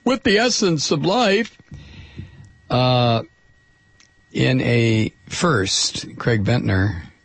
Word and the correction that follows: Venter: or Venter, the guy I'm talking about here, has made a synthetic cell from scratch.